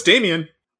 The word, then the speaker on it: Damien: Damon, son of Satan. – Oh, no, it's Damien. – Oh, is it? – Yeah, it's straight up Damien. – Okay, I thought it's spelled different. That's why I thought it was pronounced different.